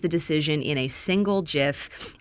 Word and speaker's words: GIF: Jeremy Slevin, I have changed how I pronounce GIF. I used to say GIF. I'm now saying GIF for you. – GIF is correct.